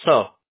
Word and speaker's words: Seo: Yeah, I'm half Korean. It's pronounced So for me.